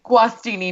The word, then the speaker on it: Guastini: Guastini.